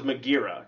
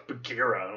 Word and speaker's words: Bagheera: I thought it was Magheera, because that's what I kept hearing. And then everyone's like, no, it's Bagheera.